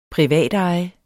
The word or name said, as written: privatejet